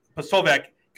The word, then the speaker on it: Posobiec: And, like, you hear, like, was it Jack Polvetz? How do you say his last name? – Polvetz? – Yeah. Posobiec got arrested because at the Davos event.